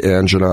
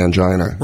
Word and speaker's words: angina: Say angina, I say angina. – Right. – You can pronounce it either way.